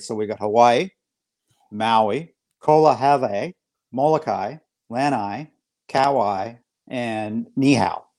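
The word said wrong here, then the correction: Kahoʻolawe: In Hawaiian, we pronounce every single syllable. So it's Kohoʻolawe, it's Lanai, it's Kauai, and Molokai.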